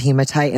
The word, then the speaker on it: hematite: if I'm pronouncing it right, hematite? – Hematite. Love hematite.